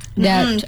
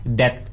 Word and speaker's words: debt: Debt. – Yeah, but it's actually read as debt. D-E-T, debt. Mm-hmm. Same thing with this receipt. Mm-hmm. So yeah, I think it's the P and T is silent.